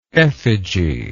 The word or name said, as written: effigial